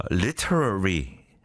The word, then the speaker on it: literary: The thing is, if you pronounce it the American way, which pronounce a T as a lil, right? 이게 if you pronounce it the British way, it's kind of easier. Literary, still difficult, but easier than literary, right?